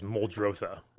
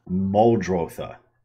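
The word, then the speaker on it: Muldrotha: You can pronounce it any way you want, but I think the real terminology is Moldrotha. – Moldrotha.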